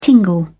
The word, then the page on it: tingle: British English